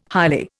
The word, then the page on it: highly: Indian